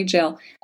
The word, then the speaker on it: gaol: It was originally opened in October of 1861, and at that point it was known as the Ararat County Jail, and that is G-A-O-L, so I hope I'm saying it correctly, but Ararat County Jail, and it housed all levels of criminals, from petty thieves to murderers.